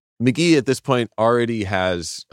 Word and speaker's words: McGee: It's called Pronounced McGee, spelled M-C-G-E-E.